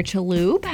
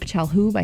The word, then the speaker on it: Chalhoub: She and her billionaire heir boyfriend, Henry Jr. Chaloub, have broken up. Chalhoub, I think is how you say that.